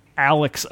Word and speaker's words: Alex: Now, you can still make fun of anyone who calls Alex Oklahoma Alec, because those people are fucking idiots.